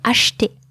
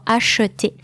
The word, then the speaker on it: acheter: So the pronunciation is the what I call the minimal sound of the schwa, it's E. And if you can actually not pronounce it at all, you don't even have to. You can say acheter or acheter. Both are correct.